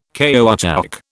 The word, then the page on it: caoutchouc: British English